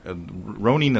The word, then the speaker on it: Ronin: Ronin is how they pronounce it, so that's how I choose to pronounce it. – Oh, okay. Right, but it's spelled Green Ronin. – R-O-N-I-N.